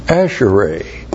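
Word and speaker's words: Asherai (אַשְׁרֵי): Asherai. A-S-H. Shava, R, long E, Asherai.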